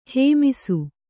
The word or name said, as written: ಹೇಮಿಸು